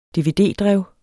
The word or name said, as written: dvd-drev